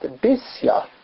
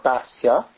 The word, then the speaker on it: Bisya: If you look in Scripture, her name is only mentioned in Divrei Hayamim and Chronicles, and there it's actually pronounced Bisya, not Basia, but Bisya, with a chirik, an E, under the bays, not an A.